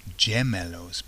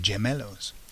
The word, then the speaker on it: gemelos: Now when you see the word, it looks like gemelos because it's G-E, G-E-M, gemelos. So you can think of, often there are gems inside of cufflinks, aren't they? But of course it's pronounced gemelos, and the word to remember that is that the cufflinks go on the hem of the shirt cuffs. It's on the hem, isn't it?